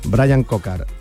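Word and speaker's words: Bryan Coquard: Ha ganado la etapa, esta etapa, la octava, entre Pusol y Seraco, Kokuar, el... Seguramente no se dice así, ¿eh? Pido disculpas. El ciclista francés. – Brian Cocard, que es precisamente el que está hablando, que imagino que no sé si se le entiende o no.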